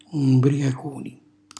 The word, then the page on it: mbriacuni: Calabrese